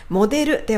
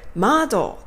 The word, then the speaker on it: model: モデルではなく、マードという風に発音するような感じです。